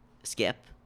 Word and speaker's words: SCP: Skip. SCP. – SCP. I had no idea that people pronounced it Skip.